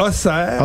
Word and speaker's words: Auxerre: On a ici un côte d'Auxerre, donc des D-A-U-X-E-R-R-E. E e. – Donc, la ville d'Auxerre. Mais c'est ben difficile. On, Auxerre. Mais c'est Auxerre. En fait, tous les X sont, sont, sont souvent prononcés en S.